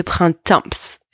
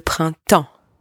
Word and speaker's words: printemps: First, do not pronounce the letter P and the letter S in the word printemps. It's not le printemps, okay? Le printemps. The P and the S are silent here.